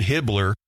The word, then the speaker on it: Hibbler: Barbash and Landau submitted this outline in 1974, later that year, and producer Winston Hibbler, emphasis on the B there, B, not T, Winston Hibbler